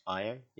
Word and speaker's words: Io: So I watched the movie Io, spelled like EO if you're an NXT wrestling fan, but apparently it's pronounced Io the other day.